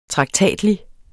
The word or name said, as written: traktatlig